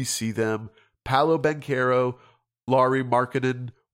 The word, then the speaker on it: Markkanen: Mark, is it Laurie Markkanen? Yeah, but it's Markkanen. – Markkanen. Okay, we've gotten that one right.